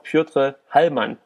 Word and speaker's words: Piotr Hallmann: Al Iaquinta trifft auf Piotr Hallmann, wie wir es natürlich richtig ausgesprochen haben.